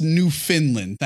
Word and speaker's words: Newfoundland: And it's pronounced Newfoundland.